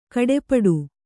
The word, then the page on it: ಕಡೆಪಡು: Kannada